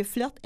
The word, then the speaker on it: flirte: Donc, de là, peut-être l'idée que quand on parle d'un flirteur ou d'une flirteuse, donc celui ou celle qui flirte, parce que flirt, F-L-I-R-T-E, est aussi accepté donc, dans le dictionnaire. Donc, on peut l'écrire bien que... – F-L-I-R-T-E. – Oui. – Mais on prononce flirt.